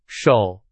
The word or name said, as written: 首